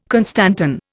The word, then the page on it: constantan: Indian